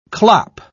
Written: clop

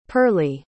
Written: pearlie

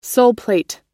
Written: soleplate

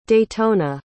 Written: daytona

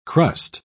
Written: crust

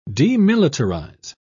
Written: demilitarize

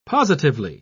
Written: positively

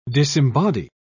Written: disembody

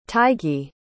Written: taigi

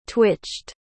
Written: twitched

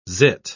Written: zit

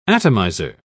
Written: atomizer

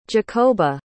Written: jakoba

